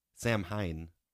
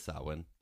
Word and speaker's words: Samhain: I don't want to stop you, but I think it's pronounced Samhain or Samhain. – It is Samhain. Yeah. I just said it wrong the first time.